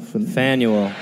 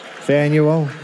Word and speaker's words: Faneuil: Faneuil. – Faneuil? – Yeah. – Faneuil. – It's now a complex. Just think of putting a fan on a mule and then let that sort of guide you.